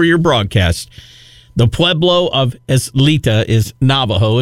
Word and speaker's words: Isleta: Eslita? Is that how you say it? Eslita? That's how I heard. – Isleta.